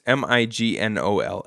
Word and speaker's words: Mignola: It's Mignola. – But the G, I think, is silent. Mignola.